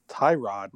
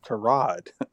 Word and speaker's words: Tyrod: Remember, he was on Hard Knocks, the HBO thing, and he said, everybody calls me Tyrod. My name is Tyrod.